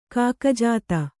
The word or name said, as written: ಕಾಕಜಾತ